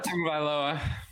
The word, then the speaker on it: Tagovailoa: But Tagovailoa.